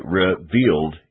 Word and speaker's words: revealed: We say revealed in two syllables. but it's sung revealed.